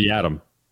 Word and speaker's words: Yiadom: Out of all those on the cut list, Yiadom, what's the correct pronunciation? – I go with Yiadom.